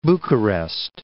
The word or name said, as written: Bucharest